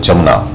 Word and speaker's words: Yamuna: Once the great Rishi Vyasa, he was sitting on the bank of the river Jamuna. Jamuna or Yamuna, some people pronounce it Yamuna, some pronounce it Jamuna.